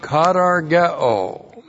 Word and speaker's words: katargeo: We have the verb katargeo. K-A-T-A-R-G-E, long O. Katargeo.